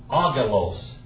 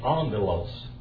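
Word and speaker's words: angelos (ἄγγελος): Agalos. Agalos. But that's not the way you say it. You say it angelos.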